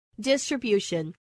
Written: distribution

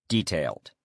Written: detailed

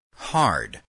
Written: hard

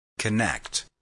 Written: connect

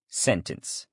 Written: sentence

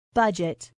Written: budget

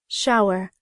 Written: shower

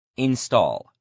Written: install

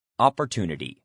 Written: opportunity

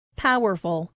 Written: powerful

